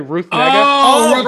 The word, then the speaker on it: Ruth Negga: Ruth Negga.